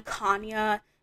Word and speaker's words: Kanya: I was so offended reading that because my name is Kanya. And first of all, I don't know how Sonia is pronounced because there's like Tanya, and then I pronounce mine Kanya,